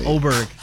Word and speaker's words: Åberg: But, yeah, Åberg, is that how you say it? – Yes, that is how you say it. – Åberg.